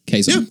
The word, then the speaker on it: Kazon: Did I pronounce that right? Is it Kazon? – Mm-hmm. – Kazon. Yeah, lovely stuff.